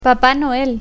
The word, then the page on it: papa noel: Spanish